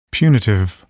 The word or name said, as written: punitive